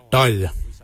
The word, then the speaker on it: dhaoly: Your quan is spelled G-W-A-N. Quan is your backside. Thigh. We say dol, dol. Dol is spelled D-H-A-O-L-Y.